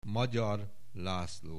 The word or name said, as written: Magyar László